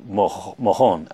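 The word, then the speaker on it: Morón: Anyway, our bus that day went to a town called Mohon, I think they pronounce it, but it's spelled M-O-R-O-N, so Moron. Moron or Mohon is the biggest city in the region.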